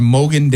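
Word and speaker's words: Mogen: The shipping label is Mogan. And maybe I'm saying that wrong. It's M-O-G-E-N. But that spells like Mogan, yeah.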